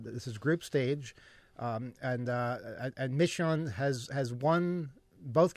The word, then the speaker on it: Midtjylland: Michelin. – Okay, the Danish team. MIDTJYLLAND. That's why I have trouble pronouncing it, because that's how it's spelled.